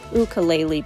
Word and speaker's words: ukulele: Our send-off today features a local resident of Hawaii on the island of Oahu, who's an ukulele player. I did say that right. That's how they say it.